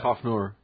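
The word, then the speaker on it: Coughenour: How would you pronounce that? – Kofnor. – Kofnor? – Or Kofnor, yeah.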